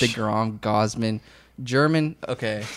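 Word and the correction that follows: Germán: How do you say his name? – I think it's Herman. – Herman. Yeah. Herman, yeah.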